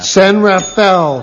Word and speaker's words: San Rafael: San Rafael is exactly right.